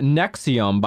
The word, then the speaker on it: NXIVM: I would just like to pause for a second and just tell you that Nexium, by the way, is spelled NXIVM in all capitals.